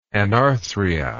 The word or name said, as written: anarthria